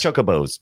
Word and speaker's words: Chocobos: I never played Secret of Mana, so it was fun digging deep into the characters, the chobos and all that. Am I saying that right? – Chobos? – Chocobos. – No, chocobos are also, chobos may be a... – Chobos is definitely not it.